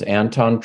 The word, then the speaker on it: Anton: My name's Anton Troyer. So A-N-T-O-N. Last name is spelled T-R-E-U-E-R.